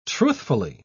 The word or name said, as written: truthfully